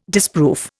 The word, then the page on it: disproof: Indian